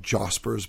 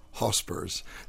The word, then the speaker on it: Josper: I call them Jospers, but they're actually pronounced Hospers.